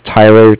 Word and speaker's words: Tyler: And if you could just put down that my name is Tyler, T-Y-L-E-R, and I'm calling with Cardiff. It's spelled C-A-R-D-I-F-F, as in Frank.